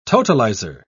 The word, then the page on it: totalizer: British English